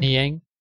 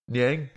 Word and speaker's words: Niang: But then, yeah, you're right about the how do you say his name? What was the guy's name? Nguyen? – Niang. – Niang? – Yeah.